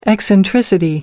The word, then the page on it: eccentricity: American English